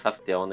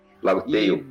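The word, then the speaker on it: Laugh Tale: A pronúncia não é mais Raftel, né? É... – Não, é. – La, la, Lago Tal. – Lago Tal. Pra Lago Tal. Lago Tail.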